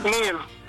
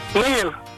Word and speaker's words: Neil: Neil. Neil.